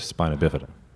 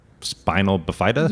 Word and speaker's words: spina bifida: Thank you for pronouncing that right. – How do they say it? – Oh, man. I get it all. – Spinal bifida? – Yeah. Spinal bif.